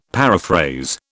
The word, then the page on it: paraphrase: British English